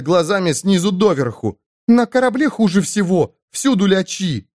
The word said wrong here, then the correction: личи: Личи. Правильно говорить, личи.